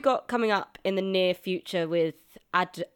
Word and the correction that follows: Aderyn: Aderyn. I knew I'd said it wrong. That's why I said it like a robot.